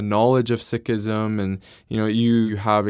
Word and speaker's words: Sikhism: um, so anyway, so it's not Sikhism; it is Sikhism. Sikhism. Yeah, mm-hmm. um, even though you spell it and it may sound like it's C S I, Sikhism, mm-hmm.